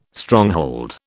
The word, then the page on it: Stronghold: British English